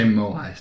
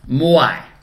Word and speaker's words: Moai: I'm going to say Moai statues. – Moai. – Moai statues. – Moai. All right, cool.